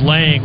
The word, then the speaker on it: Lange: And now it is Lang, which is spelled L-A-N-G-E.